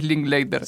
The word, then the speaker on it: Linklater: Creo que la pronunciación oficial es Linklater. – Sí, sí. Él lo eh, pronuncia así, por lo menos.